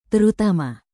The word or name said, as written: terutama